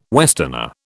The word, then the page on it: westerner: British English